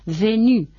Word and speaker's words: venu: venu, devenu.